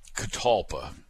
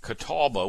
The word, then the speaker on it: Catalpa: And Catalpa, let's call it that. C-A-T-A-L-P-A. And it gets confusing because a lot of people write it and pronounce it Catawba, which is the name of a place in North Carolina, which has nothing to do with Catawba trees.